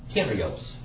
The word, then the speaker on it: Kyrios: Kappa, iota. Or kappa, ypsilon, rho, iota, omicron, sigma. Kyrios. All right? Now, if you took his Greek, he would say Kyrios.